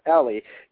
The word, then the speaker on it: Eli: Now, if you guys, you know, reading this, it's pronounced Ellie. It's E-L-I. You know, a lot of people probably pronounce that Eli. – Uh-huh. – You know, but it's not Eli. It's Ellie.